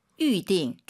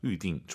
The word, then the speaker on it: 预定: 아, 预定. YU 발음이에요. 위, 위 하지 마시고 다시 한번. – 预定. – 둘 다 사성이에요. 검색해 보세요, 여러분. YU로 시작합니다. 预定.